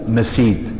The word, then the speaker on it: مسيد: فإذا المسجد هو هذا، أنه يقال له مسيد بفتح الميم والياء وبالياء المكسورة بدل الجيم، مسيد.